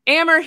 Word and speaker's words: Ammer: Anyway, we've got our friend Ammer here, and I confirmed before the show that that's how he pronounces his three-letter name. So it's AMR. His name is pronounced Ammer, but he goes by the internet guy because that's way catchier, and people couldn't figure out how to pronounce his name.